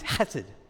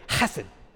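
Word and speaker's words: chesed (חסד): Turn to the person next to you and say, chesed. Make sure you spit on them to say it right. You got to have a little bit of saliva to do it well.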